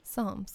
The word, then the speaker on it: Psalms: Psalms. – Well, it starts with a P. It's a silent P.